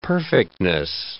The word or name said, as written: perfectness